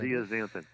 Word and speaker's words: zeaxanthin: But you talked about luteins and zeaxanthins. I said that correctly, I think. – Zeaxanthin, yes.